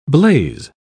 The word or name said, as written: blaze